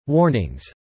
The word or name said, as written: warnings